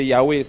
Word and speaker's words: Yahweh: But they did not know me as, depending on the pronunciation you want to use, the same thing. Some will say Yahweh, some will say Jehovah.